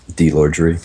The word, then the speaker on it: Dlorgere: Delorgere. – And spell it for people. – D-L-O-R-G-E-R-E.